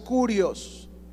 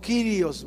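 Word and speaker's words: kurios (κύριος): Si se fijan, dice a nuestro Señor Jesucristo, también la palabra soberano, que es kurios, con K, K-U-R-I-O-S. Algunos le pronuncian como kirios, ¿verdad? Pero se deletrea kurios, con K, K-U-R-I-O-S, ¿ya?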